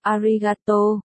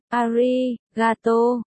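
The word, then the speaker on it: arigato (ありがとう): Aijigato, Leo đánh vần, no, arigato. Mayer nghe cách phát âm sai. Nhíu mày chỉ lại, arigato, Leo cố gắng đọc, sai, là arigato, đọc lại, ariga, cái tô, không, không có cái tô, arigato, ari, và thế là cuộc giảng dạy ngôn ngữ phát âm giữa Mayer và Leo bắt đầu.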